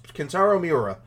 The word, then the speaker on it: Kentaro Miura: His name is, let's see if I can pronounce it right, Kentaro Miura. Is that, am I saying it right? – Yeah, pretty good. Pretty close. Kentaro Miura. – Miura, yeah. so,